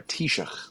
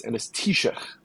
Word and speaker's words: Taoiseach: But yeah, sorry, Taoiseach, that's how you say it. That was a whole thing in Seth Meyers's corrections, which was Oh, really? – how do you say the name of the Irish Prime Minister, right? And it's Taoiseach. – Okay. – There you go. But it's spelled TAOISEACH.